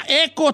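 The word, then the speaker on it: Echo: Se llama Eco 3, pero se escribe hecho sin H, e, E-C-H-O 3, Eco 3, con Luke Evans, eh, que es el mero perrón allí.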